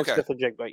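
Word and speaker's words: Smith-Njigba: Yeah, so you put an emphasis on the N and then everything else is just pronounced the way it is. But Kanan Smith Njigba, yes.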